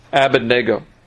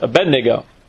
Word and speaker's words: Abednego: I pronounced it differently because it is to be pronounced differently, but you probably heard it as Abednego.